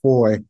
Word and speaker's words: Fahie: They can also find me on LinkedIn, Facebook, Instagram, just my name, Vaughn, pronounced Foy, and you did awesome. Thank you. It looks like Fahie, but it's pronounced Foy from St. Thomas, the Virgin Islands.